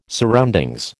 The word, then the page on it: surroundings: American English